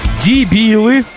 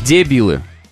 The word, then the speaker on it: дебилы: Вы дебилы бюджетные. Две ошибки у человека. Во-первых, дебилы, а не дебилы.